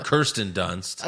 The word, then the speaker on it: Kirsten Dunst: Judy Dench, Belfast, Kristen Stewart, Power of the Dog, or Kristen Stewart, Kristen Dunst, or Kirsten Dunst.